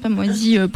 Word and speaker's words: MWASI: Alors ça s'écrit MWASI. C'est pas Moisi pourri.